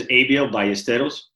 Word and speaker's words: Abiel Ballesteros: My name is Abiel Ballesteros.